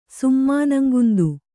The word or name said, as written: ಸುಮ್ಮಾನಂಗುಂದು